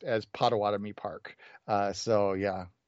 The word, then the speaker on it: Potawatomi: It actually means waterfall in Potawatomi. – Potawatomi.